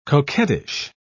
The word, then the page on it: coquettish: British English